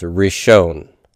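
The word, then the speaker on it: rishon (ראשון): And then finally, for other forms, rishon, rishon. The aleph there is quiescent, so you don't pronounce it.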